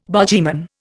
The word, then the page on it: bogyman: Indian